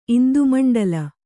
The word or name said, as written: ಇಂದುಮಂಡಲ